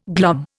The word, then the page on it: glom: Indian